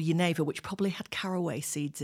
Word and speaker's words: Genever: It's spelled G E N E V E R. So an English person might call it Geneva.